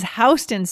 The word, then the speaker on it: Houston: H-O-U-S-T. Oh, yeah. T-O-N. – Okay. It is not like the state of Texas, guys, like the city in Texas. It is Houston Street. So important, because if you say it wrong, people know you're a tourist.